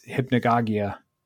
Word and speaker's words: Hypnagogia: And that's Hypnagogia. – Yeah. – I'm saying that right, right? – That's how I would pronounce it.